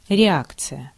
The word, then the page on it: réaction: France; /ʁe.ak.sjɔ̃/